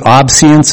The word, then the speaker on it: obeisance: To do, I believe, obeisance is the proper pronunciation.